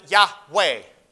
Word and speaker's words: Yahweh: they would pronounce it Yahweh. But other Hebrew words, they would pronounce things, that W with a V sound. That's the Hebrew letter that's pronounced as a V, not as a W in English.